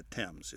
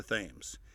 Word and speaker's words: Thames: It's not pronounced like the river in London, the Thames. It's the Thames.